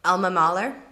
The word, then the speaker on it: Mahler: But I thought we're going to have the lady that rhymes with... – Alma Mahler. – Yes. I'm like, it's pronounced Alma Mater.